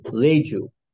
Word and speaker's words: Reiju: Only they called them Leiju, which is starts with an R-E-I-J-U, Leiju, it's pronounced.